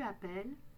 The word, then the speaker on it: appelle: on prononce un « e » et on double le « l ». On prononce donc « appelle ». J'appelle, tu appelles, il »,« elle », »,« on » appelle, il »,« elles » appellent.